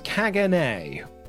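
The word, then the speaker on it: Caganer: It is pronounced Kaganay.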